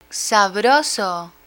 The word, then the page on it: sabroso: spanish